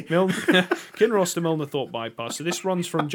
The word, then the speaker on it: Milnathort: Milnathort.